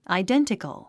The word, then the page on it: identical: aidéntik ə l; アイデンティコゥ